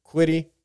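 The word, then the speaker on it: Kwity Paye: So when I watched that, it was, I mean, Quidipe. I believe that I'm saying it white. That's definitely saying it correct, saying it right. Quidipe.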